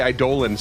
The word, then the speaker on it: Eidolon: Yeah, so I call it Eidolon as well, but the proper pronunciation is apparently Eidolon.